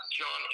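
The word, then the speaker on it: Jon: It's at the John Anderson. Keep in mind, my name is spelled most truly, so it's J-O-N, and Anderson is A-N-D-E-R-S.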